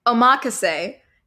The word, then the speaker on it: omakase: He originally wanted to call it, and I'm going to butcher this, omakase, a term used to describe high-quality sushi selected by the chef. – Can you spell that? – O-M-A-K-A-S-E.